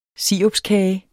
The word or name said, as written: sirupskage